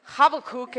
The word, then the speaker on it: Habakkuk: So just know Habakkuk is how you say it in Hebrew, so that might come out a couple times as well.